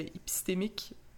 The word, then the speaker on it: épistémiques: injustices euh, épistémiques. Est-ce que je le dis bien maintenant? Oui, oui, c'est parfait.